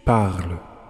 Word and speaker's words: parles: Tu parles. – Sounds the same, but it's spelt P-A-R-L-E-S.